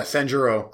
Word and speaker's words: Sanjuro: Yeah, Sanjuro.